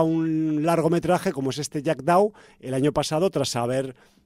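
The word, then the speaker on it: Jackdaw: Jack Dhow, como suena, J-A-C-K-D-A-W, a pesar de mi mala pronunciación del idioma shakespeariano.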